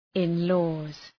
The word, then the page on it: in-laws: ın’lɔ:z